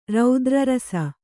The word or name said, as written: ರೌದ್ರರಸ